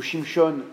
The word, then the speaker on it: Shimshon: le récit de Samson, ou Samson, selon la prononciation, ou Shimshon en hébreu,